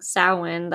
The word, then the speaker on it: Samhain: and it's spelled Samhain, but it's pronounced Samhain,